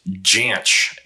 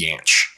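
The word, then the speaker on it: Jansch: And I did look that up because I thought it would be pronounced differently, but apparently he pronounced it with a hard J, Janch. – Hmm. – Apparently the more common way is Yanch.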